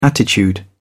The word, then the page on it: attitude: British English